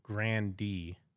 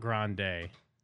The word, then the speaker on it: Grande: Yeah, no, Ariana said the actual pronunciation is Grande. – Fuck! – Not Grande.